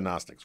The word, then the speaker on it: Gnostics: Is it a silent G? Anywhere you want to go, but explain to people who the Gnostics were.